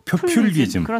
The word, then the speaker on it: 표퓰리즘: 표 발음도 어려요. 표플리즘. – 표퓰리즘. 그렇죠. 예.